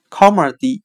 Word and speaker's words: comedy: 我们可以用谐音法来记忆这个单词 comedy，它的发音很像靠末地，依靠的靠，幽默的默。